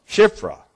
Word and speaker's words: Shiphrah: I didn't say that right. Help me, please. Chapter 1, verse 15. Well, turn over there. Exodus 1, verse 15. Shiphrah. All right, I got one too many syllables in it.